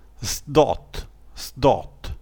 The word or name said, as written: zdot